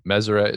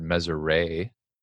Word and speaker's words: Meseret: I've heard, so Meseret is the correct way to pronounce it. I've heard Meseret, where a lot of people think the T at the end is silent. – French, yeah. – Which I'll give you that. I've heard Meseret, where they just replace the R and the S. They just switch it.